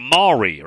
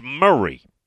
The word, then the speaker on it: Murray: Muletown, Tennessee, where Kevin resides, is M-A-U-R-Y County, which you would think that would be Maury or something, right? It's Murray. It's Murray County.